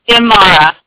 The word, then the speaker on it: Imara: Amara. I-M-A-R-A.